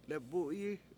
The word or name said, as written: lebU7yi